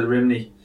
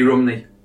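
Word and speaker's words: Rhymney: But basically, apparently, the Birds version of the song mispronounces the word Rimney. It should be Rumney in the proper Welsh.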